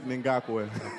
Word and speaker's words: Ngakoue: Yannick Ngakoue. – I was going to ask how long it took you to actually be able to say that properly. – Go, say it. – Yannick Ngakoue.